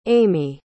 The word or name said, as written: aimee